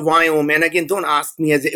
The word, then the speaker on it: Viome: So, the Indians can't pronounce the word we. So we as in Victor, I-O-M-E. So it's called Wyome. And I know you're probably thinking that's not spelled Wyome, but that's okay. That's how it is.